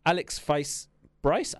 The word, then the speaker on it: Alex Feis-Bryce: Alex Feis-Bryce.